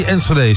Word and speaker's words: Enschede: 180 Enschede.